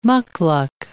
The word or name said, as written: mukluk